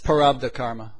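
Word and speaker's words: Prarabdha: that in the East they call it the prabdha. I can't pronounce Parabdha karma. – Parabdha karma.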